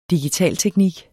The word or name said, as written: digitalteknik